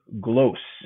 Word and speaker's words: Głos: Canadian Polish language newspaper called Głos. Rhymes with post, but with no T and a G, and lots of anti-Semitism, as it turns out.